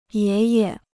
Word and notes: 爷爷: yé ye

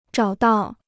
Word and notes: 找到: zhǎo dào

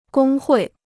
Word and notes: 工会: gōng huì

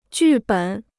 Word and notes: 剧本: jù běn

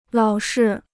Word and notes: 老是: lǎo shi

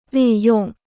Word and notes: 利用: lì yòng

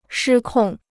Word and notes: 失控: shī kòng

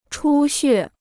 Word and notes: 出血: chū xuè

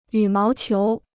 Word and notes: 羽毛球: yǔ máo qiú

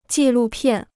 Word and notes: 纪录片: jì lù piàn

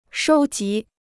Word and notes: 收集: shōu jí

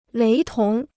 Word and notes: 雷同: léi tóng

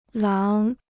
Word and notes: 狼: láng

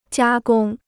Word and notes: 加工: jiā gōng

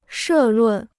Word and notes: 社论: shè lùn

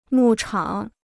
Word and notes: 牧场: mù chǎng

